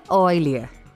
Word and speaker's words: Euler: Então, Henrique, se escreve Euler, mas se pronuncia Euler.